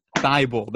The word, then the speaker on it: Thybulle: Yeah, Matisse Matisse Thybulle.